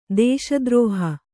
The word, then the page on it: ದೇಶದ್ರೋಹ: dēśa drōha